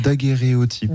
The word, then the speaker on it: daguerréotype: alors du coup j'ai peut-être mal prononcé tout à l'heure. – Non, c'est ça, daguerréotype.